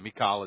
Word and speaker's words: Michal: I need Michal, however you want to say her name. Michal is the Hebrew pronunciation.